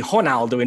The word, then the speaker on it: Ronaldo: So it's like you're not worried about the biggest star in the world saying his name authentically correctly, because if you're applying that formula to his name, it would be Ronaldo. In Portuguese, the R is pronounced like an H, but that's okay with the biggest star in the world to anglify it or whatever.